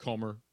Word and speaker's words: Comer: Something like Comer, like rhymes with Gomer, maybe. – Like Homer, Kentucky. But Comer.